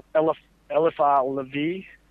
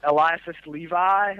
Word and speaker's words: Eliphas Levi: And they picked up on that idea from a man named Eliphas Levi, I think is how you would pronounce it in French. – Yeah. – Eliphas Levi, a lot of people will say.